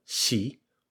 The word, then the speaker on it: shi: When we counted, we said ichi, ni, san, shi, where shi is 4, then go, roku, shichi, where shichi is 7. So 4 and 7 in this example are shi and shichi.